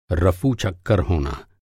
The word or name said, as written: रफ़ू-चक्कर